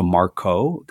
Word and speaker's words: Marco: I say it quick, and what they hear as opposed to Marco, they hear yeah.